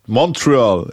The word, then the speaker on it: Montreal: Genau, und halt Modeling und steht finanziell auf jeden Fall gut da, ist unabhängig, komplett, lebt in ihrer Mini-Wohnung da in Montreal, Montreal, im Englischen wird es ausgesprochen mit T.